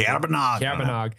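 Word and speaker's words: Caerbannog: Luffy, hunter Yakin Hagar, pet the Rabbit of Cair Banog. I don't even know if I'm pronouncing that correctly. – Cair Banog.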